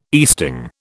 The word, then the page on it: easting: British English